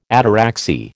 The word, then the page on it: ataraxy: American English